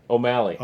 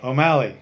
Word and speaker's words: O'Malley: McCulley? – I knew it until you said it wrong. O'Malley. – O'Malley.